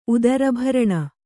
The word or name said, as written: ಉದರಭರಣ